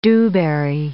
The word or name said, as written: dewberry